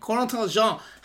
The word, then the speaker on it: Corentin Jean: That he has signed, Corentin Jean has signed with Inter Miami on deal from Lens, if I'm not mistaken, in France.